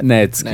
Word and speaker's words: Netsky: Nexty. N Nexty. – Ah, Netsky.